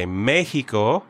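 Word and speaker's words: México: In Spanish you say Mexico. – There you go. – So that X in Mexico does not make the X sound.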